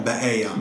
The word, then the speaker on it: ba'aya: How do I pronounce that? – In Arabic? – Yeah. – Ba'aya. – Ba'aya.